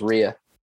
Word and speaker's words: Rhea: Everyone in the world, when they see Rhea, says Ray. So, it's pronounced Rhea.